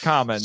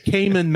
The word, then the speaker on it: Kamen: It's pronounced Kamen. – Show me, guys! – Don't make me. – Next thing you're going to tell me is you call him Cayman Mask.